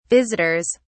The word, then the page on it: visitors: British English